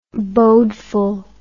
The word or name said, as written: bodeful